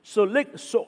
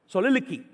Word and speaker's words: soliloquy: Soliloquy. Soliloquy. There you go. Soliloquy. It's spelled this way. S-O-L-I-L-O-Q-U-Y.